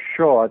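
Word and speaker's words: Shor: Uh, that was a theoretical idea, but what really got interest going was in 1994 when Peter Shaw, that's S H O R, I pronounce that in a funny way because I grew up in New York.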